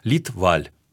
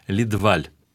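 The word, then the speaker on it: Лидваль: Шведы произносят фамилию фактически с двумя ударениями, с придыханиями. Ледваль. А что касается самого Федора Ивановича, который, понятно, был не Федор Иванович, а Фредерик, он произносил фамилию все-таки Ледваль.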